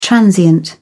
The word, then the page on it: transient: British English